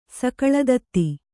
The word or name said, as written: ಸಕಲದತ್ತಿ